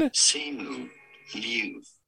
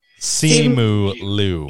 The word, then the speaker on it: Simu Liu: Simu Liu. – Simu Liu. – Pretty straightforward once you know. – Is that what I said? I thought I said. – Yeah, that sounds right. – Yeah, Simu Liu is absolutely wonderful.